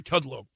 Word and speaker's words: Kudlow: So again, this interview with Kudlow, I always mispronounce his name. It's Larry Kudlow.